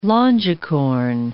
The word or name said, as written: longicorn